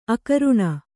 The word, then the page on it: ಅಕರುಣ: akaruṇa